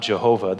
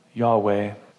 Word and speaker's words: Yahweh: We know it's not Jehovah. There's just no J in Hebrew. Yahweh, understanding that Yahweh is the covenant name of the God of Israel.